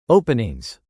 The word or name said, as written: openings